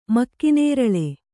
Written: ಮಕ್ಕಿನೇರಳೆ

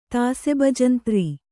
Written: ತಾಸೆಬಜಂತ್ರಿ

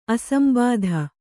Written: ಅಸಂಬಾಧ

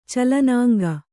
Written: ಚಲನಾಂಗ

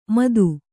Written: ಮದು

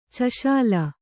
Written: ಚಷಾಲ

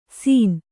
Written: ಸೀನ್

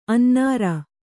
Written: ಅನ್ನರ್

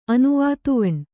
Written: ಅನುವಾತುವಣ್

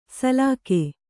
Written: ಸಲಾಖೆ

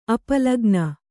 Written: ಅಪಲಗ್ನ

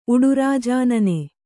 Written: ಉಡುರಾಜಾನನೆ

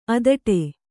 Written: ಅಧಟು

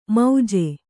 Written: ಮೌಜೆ

